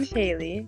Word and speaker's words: Hailee: I'm Haley. It's spelled H-A-I-L-E-E.